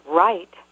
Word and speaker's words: Wright: That's Nina, which rhymes with the more common name Tina, and Wright, which sounds like what I do for a living, or the opposite of wrong, but is spelled differently than both of those. It's spelled the same as the last name of the Wright brothers who invented the airplane.